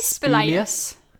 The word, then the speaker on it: spelaeus: How would you pronounce that? Spilaeus? – How do you spell it? – S-P-E-L-A-E-U-S. – I'd say spilaeus. – Spilaeus? I think spilaeus.